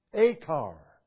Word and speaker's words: achar: The word trouble is from a Hebrew verb that is pronounced achar, meaning to trouble, and it's from that verb that the name Achan is derived.